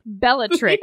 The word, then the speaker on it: Bellatrix: Bellatrix.